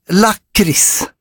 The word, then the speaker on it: lakrits: Jag noterar att allt fler yngre stockholmare använder det korrekta lakritsuttalet lakrits. Välkommen. Nu är det bara kex och kaviar kvar. – Jaha, nu måste man ju inte börja. Eller ja, vi säger ju lakrits, kex och kaviar.